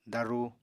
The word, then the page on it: daruu: apatani